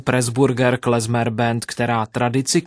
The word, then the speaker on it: klezmer: Vedle hudby samotné šíří mezi své příznivce i spoustu užitečných detailů, třeba to, že slovo klezmer pochází z hebrejštiny a vyslovuje se se z a nikoli s c.